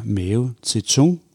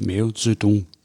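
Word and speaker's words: Mao Zedong: Og det betyder blandt andet, at navne som mave til tung, som jeg ville udtale, hvis jeg skulle læse de bogstaver, der stod der, mm. – det nu skrives mave til dong, altså med et sæt, mm. og udtales mave til dong i lydskrift.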